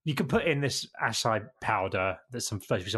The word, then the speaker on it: acai: I think I pronounced it acai bowl, but it's acai bowl, and I think it might be Hawaiian or Brazilian or something they come from.